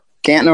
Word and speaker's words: Canton: And Joe, did I say it right? It's Canton, Ohio, where you are? – Yep. Canton, Ohio.